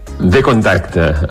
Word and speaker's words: Vkontakte: Què és exactament, a veure si ho sabré pronunciar bé, la xarxa social Vkontakte? – Vkontakte.